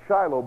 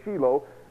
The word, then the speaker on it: Shiloh: Maybe you pronounce it Shiloh, but in Hebrew it's Shiloh.